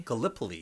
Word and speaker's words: Gallipoli: Gallipoli.